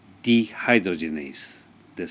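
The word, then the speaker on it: dehydrogenase: ディヒドロゲナーゼはディハイドロジェネースですし、まあそういった英語的発音とドイツ語的発音ということの違いを覚えておいてください。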